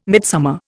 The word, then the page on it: midsummer: Indian